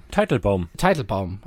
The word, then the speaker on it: Teitelbaum: Wie spricht man den aus? Du weißt sowas? – Teitelbaum. – Also okay, wirklich so.